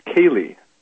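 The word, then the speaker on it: Koehli: It's actually pronounced Kaylee.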